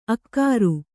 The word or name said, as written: ಅಕ್ಕರ